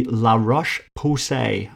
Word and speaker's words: La Roche-Posay: It's called the La Roche Posay. I'm going to spell it for you because it sounds strange. LA ROCHE-POSAY, La Roche-Posay, I'm going to say that. It's a skin tracker.